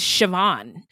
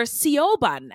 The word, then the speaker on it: Siobhan: And you know what it reminds me of is the name, and another Irish name, I think, is Siobhan. – Yeah. And it's spelled like Sioban. Sioban. Yes. And I've done a shout out to a Siobhan and called her Sioban.